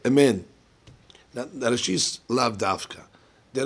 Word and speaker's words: amen: Okay, so the amen, you have to pronounce the nun, ketufa.